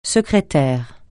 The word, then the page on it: secrétaire: francais